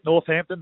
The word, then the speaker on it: Northampton: is that a great Carlton half-back emanated from Northampton. Or how do you pronounce it? – Yeah, that's it, Northampton, that's it.